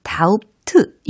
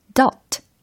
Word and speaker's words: doubt: 그래서 철자는 d o u b t 라는 철자이고 요 발음에 주의해야 되는 거 아시죠? 다우트 이게 아니라 dot 이렇게 마치 비가 없는 것처럼 발음을 해주셔야 되는 게 팁입니다.